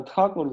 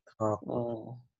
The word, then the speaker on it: Тхакур: Правильно, Тхакур говорит, не так? – Ну да, просто если посмотреть, как пишется это слово на санскрите, то там длинное А первое. Тхакур.